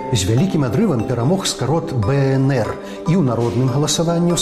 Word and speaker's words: БНР: Вимовляємо абревіатуру правильно, літера за літерой – БНР.